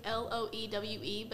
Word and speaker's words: Loewe: It's, like, Loewe, but it says it, like, Louvre.